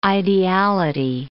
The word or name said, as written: ideality